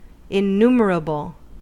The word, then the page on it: innumerable: American English